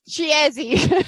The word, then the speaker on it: Chiesi: Because it's Chiesi. C-H-I-E-S-I. Casa Chiesi.